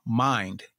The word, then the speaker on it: Mined: And so Mogul Mind came about. And the reason why it's spelled M-I-N-E-D is because when you hear it, you still hear mind.